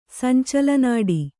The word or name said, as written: ಸಂಚಲನಾಡಿ